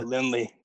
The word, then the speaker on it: Lindley: Lindley.